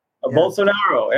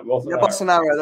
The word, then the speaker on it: Bolsonaro: So the people's choice was actually Bolsonaro, however you say his name, from Brazil. So Bolsonaro, yeah. – Bolsonaro, that's it.